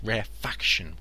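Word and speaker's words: rarefaction: I said rare fraction. It's rare faction without that second R. Rare faction. So, compression and rare faction.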